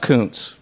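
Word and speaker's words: Kuncz: It's K-U-N-C-Z, and it's kind of, I'm sure it doesn't sound, oh, okay. – It's pronounced Kuntz, but it Kuntz. Sounds like it could be something else.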